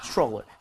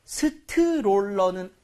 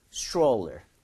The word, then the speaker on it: stroller: However, 발음할 때 스트롤러는 아니죠. – TR 들어가 있잖아요. – 아, yes. TR 뒤에 모음이 들어가 있으면 치엣 비슷하게 소리가 날 수 있습니다. 충돌 나오는 소리인데요. 한번 가볼게요. Stroller. Stroller.